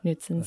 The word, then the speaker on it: Knudsen's: The K is silent. – Yeah. Knudsen's.